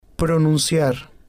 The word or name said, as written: pronunciar